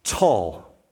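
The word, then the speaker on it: tall (טוּל): So God literally, according to this passage, throws a great wind, and the verb that's used there is spelled T-U-L in Hebrew language, in our translation, tall.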